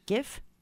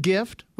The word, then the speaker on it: GIF: GIF? – Like the word gift without the T. Or is it GIF like the peanut butter?